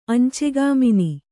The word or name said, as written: ಅಂಚೆಗಾಮಿನಿ